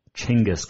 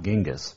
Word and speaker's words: Chinggis: We know him better as Chinggis Khan. And yes, it is pronounced Chinggis, not Genghis. It is Chinggis Khan.